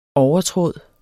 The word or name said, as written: overtråd